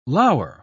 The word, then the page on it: lour: British English